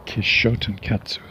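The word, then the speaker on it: Kishōtenketsu: 5 syllables, 4x. I don't think it's kit, I think it's ki. Kitshōtenkatsu. There, that's better.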